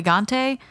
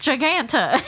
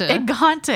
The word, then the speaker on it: gigante: He got the burrito gigante. – Yeah. – And like, giganta. – Oh, my God, said, giganta. – It's gigante, and she's like, gigante.